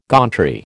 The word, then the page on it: gauntry: American English